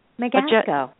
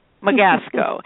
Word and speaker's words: Megasko: Her name is Angela Magesto. Am I pronouncing that right? – Megasko. Megasko.